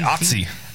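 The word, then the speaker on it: Ötzi: Scientists just discovered that yeast has been growing in the guts of a frozen mummy called Otis the Iceman. Hmm. Otis? How would you say it? – I'd say Otzi. – Otzi. – O-T-Z-I-E. – Yeah. I was saying Otis in my mind.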